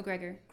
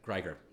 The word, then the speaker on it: Greger: Your buddy, and forgive me if I'm pronouncing his name wrong, Dr. Michael Geiger, How Not to Die. – Oh yeah, yeah, yeah, Michael Greger. – Greger.